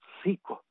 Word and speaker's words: SEECO: SICO. Se escribe SEECO, SICO.